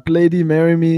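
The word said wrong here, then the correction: Bladee: Det er Må jeg, jeg lytter ikke engang til ham, det udtales Blade. – Det udtales Blade.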